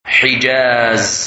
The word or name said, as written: Hijaz